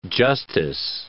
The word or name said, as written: justice